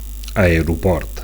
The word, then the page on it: aeroport: Catalan